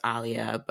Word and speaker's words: Aaliyah: And he was, because I said, what I said was, my name's Aaliyah, but it's actually pronounced Aaliyah, but nobody says it like that.